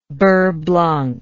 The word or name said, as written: beurre blanc